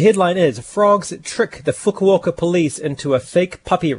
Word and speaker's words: Fukuoka: Yeah, Fukuoka is my pronunciation.